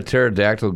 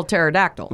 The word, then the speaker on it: pterodactyl: Why can't you hear a pterodactyl going to the bathroom? – Why? – Because the pee is silent.